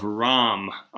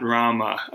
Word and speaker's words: Ram: Incidentally, it's the Epic of Ram, R-A-M, not Rama. We're using the Hindi pronunciation.